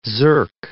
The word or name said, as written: zerk